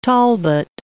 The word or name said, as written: Talbot